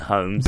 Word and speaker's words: Holmes: Why are you saying Holmes for one of them and Holmes for me? He is my dad. – He's be. Irish, isn't he? So that's the excuse I'm going to use. – So the Irish pronounce Holmes... – They go, Holmes. Yeah. – And well, they don't, do they? – No.